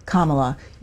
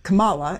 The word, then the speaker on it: Kamala: You said Kamala, and it's Kamala.